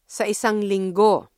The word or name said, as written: sa isang linggo